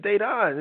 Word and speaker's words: Dedan: I'm like, it's Dayton. It's just two syllables.